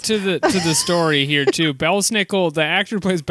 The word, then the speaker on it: Belsnickel: Belsnickel. – Belsnickel. – It's Belsnickel.